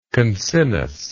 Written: concinnous

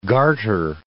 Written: garter

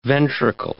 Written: ventricle

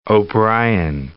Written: O'Brian

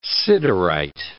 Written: siderite

